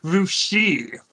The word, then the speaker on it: Russia: Oh, and the best way thing about it is it'll get really messy because it's pronounced Rushi for that little city in Ohio.